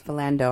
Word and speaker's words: Philando: His name was Philando. I pronounced it incorrectly, too.